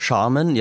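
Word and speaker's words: Sharman: Ja, und Jim Charman wird das ausgesprochen, hoffe ich doch zumindest. – Charman, ja, ja.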